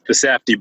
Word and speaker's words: Safdie: This week, we're looking at the 2019 Adam Sandler film, Uncut Gems, directed by Josh and Benny Safdie. Correct me if I'm mispronouncing that one. – The Safdie Bros, yep.